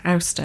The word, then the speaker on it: Asta: And amongst this group of people, there was a minister called Olafur and his pregnant wife, Asta, which I think is pronounced Auster, actually, which is quite difficult to remember when you see it written Asta in the book, and their two small children.